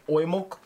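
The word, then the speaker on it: Oimelc: When I hear this pronounced, it's sort of like oimulk. – Yeah. – You know, almost like the E isn't as hard as when we say, like, milk.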